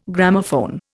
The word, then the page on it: Gramophone: Indian